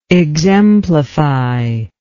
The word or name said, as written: exemplifier